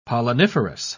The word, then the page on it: polliniferous: British English